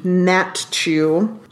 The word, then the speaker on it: Natiw: We have Jillian Natchu, really unusual spelling of her last name, Natiw. Oh. I had to look up the pronunciation, Natchu.